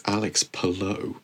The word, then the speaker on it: Palou: It just sort of, it's Alex Palou. Say it correctly.